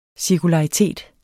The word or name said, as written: cirkularitet